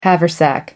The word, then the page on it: haversack: American English